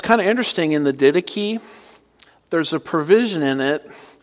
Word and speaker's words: Didache: By the way, don't pronounce that the Didache. I was talking to one of my friends on the phone and he kept calling it the Didache. And no, that's not pronounced the Didache, it's the Didache.